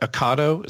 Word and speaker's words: Ocado: Now, okay, so what Ocado, is that how you pronounce it? – Yeah.